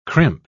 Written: crimp

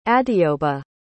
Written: adeoba

